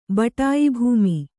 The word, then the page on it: ಬಟಾಯಿಭೂಮಿ: Kannada